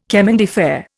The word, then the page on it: chemin de fer: Indian